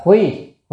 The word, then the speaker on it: khuy: Khả, chữ này đọc là khuy, khuy là có nghĩa là nhìn, có thể nhìn xem.